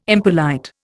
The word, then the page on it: impolite: Indian